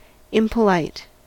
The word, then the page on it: impolite: American English